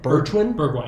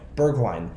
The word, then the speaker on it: Bergwijn: Another one for the future, how do you pronounce his last name? Bertwijn? – Bergwijn.